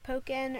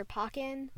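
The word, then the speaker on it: Pokkén: It can be either pronounced Pokken or Pokken. – Really? – It depends, because Pokken for Pokemon and Pokken for Tekken. – See, because when I see Pokken, P-O-K-K-E-N, I just think of the band.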